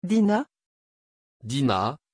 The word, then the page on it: Dinah: French